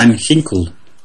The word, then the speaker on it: Ginkel: Do we pronounce the G? – It's sort of H-ish kind of thing. Van Ginkel or something. – Van Ginkel.